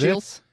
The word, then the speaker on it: ischials: Ischials, I-S-C-H-I-A-L-S, is that Ischials? – It? Ischials. – Yeah.